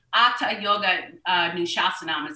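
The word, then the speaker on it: atha yoga anushasanam: Atta yoga nushasanam is how they say it.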